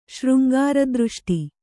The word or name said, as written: ಶೃಂಗಾರದೃಷ್ಟಿ